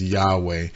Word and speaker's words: Yahweh: It's the name of God, which is always expressed by those four letters, which we transliterate in English into YHVH, and we pronounce as Yahweh.